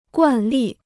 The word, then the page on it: 惯例: guàn lì